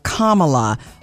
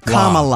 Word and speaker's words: Kamala: And it reads, my first name is pronounced Kamala, like the punctuation mark. So it's like comma. – Yeah. – Kamala.